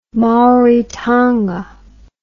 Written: maoritanga

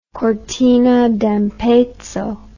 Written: cortina d'ampezzo

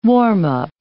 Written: warm-up

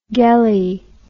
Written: gele

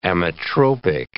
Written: ametropic

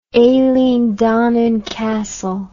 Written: eileen donan castle

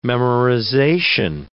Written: memorization

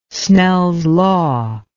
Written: Snell's law

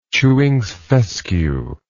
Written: Chewings fescue

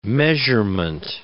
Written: measurement

